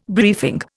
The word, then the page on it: briefing: Indian